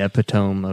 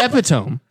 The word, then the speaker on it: epitome: Did you mean epitome? – What I said? No, the epitome.